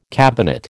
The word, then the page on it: cabinet: American English